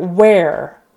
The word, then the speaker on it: where: All right, so the word where. Where are you? Where are we going? Where is it? So it's air. Um, even though it's E R E, we pronounce it as if the word air, we breathe air, right?